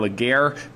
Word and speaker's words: Legere: You pronounce his name, John Legere, but he's very, very animated out there.